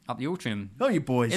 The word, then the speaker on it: Altrincham: I'm going to start throwing Altrincham. did I say that right? – Altrincham.